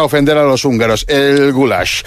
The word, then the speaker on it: goulash: El goulash es pronunciado así, goulash.